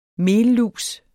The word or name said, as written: mellus